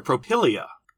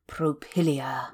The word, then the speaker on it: Propylaea: The Propylaea. Propylaea. It's spelled P R O P Y L A E A. And if that's not how you pronounce it, then blame Wikipedia because I'm, I'm oh.